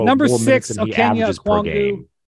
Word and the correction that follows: Onyeka Okongwu: Number six, Onyeka Okongwu. – I mean, this is the worst pronunciation of his name of all time. – Onyeka Okongwu. Sorry.